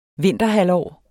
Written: vinterhalvår

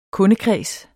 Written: kundekreds

